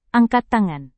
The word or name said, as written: angkat tangan